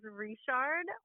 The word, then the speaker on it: Richard: well, my last name is Richard, which is a Cajun French way of pronouncing Richard.